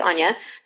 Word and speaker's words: Anna: So it's spelled Anna Reed, but it's actually Anya.